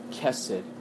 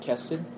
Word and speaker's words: chesed: But I think the Old Testament, there's a unique word called chesed. I know in the English it looks like an S-H, but it's pronounced chesed with a heavy K. I can't pronounce the K, chesed, you know.